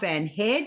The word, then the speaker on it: Van Hare: I'm so excited to be sitting here with Barb Van Hare.